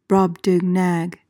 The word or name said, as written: brobdingnag